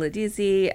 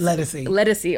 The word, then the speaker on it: Ledisi: India Arie is on the album, Ledisi, and this Ledisi.